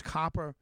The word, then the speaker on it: Copper: John Hendricks and Terrence Copper. It's spelled like Cooper if you're going to look him up, but it's Copper.